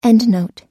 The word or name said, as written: endnote